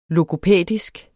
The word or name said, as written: logopædisk